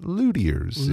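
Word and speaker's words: luthier: No, I actually looked up the pronunciation for this, L-U-T-H-I-E-R. I've seen that word several times over the years. It's someone who makes guitars, but it's pronounced luthiers.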